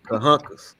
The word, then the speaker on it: Carrancas: I know the R is silent. – Carrancas. – Carrancas. – Yeah. Carrancas. Yeah. – So the R, H. – Yeah. Good. – The R got an H sound in Brazil.